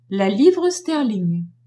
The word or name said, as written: livre sterling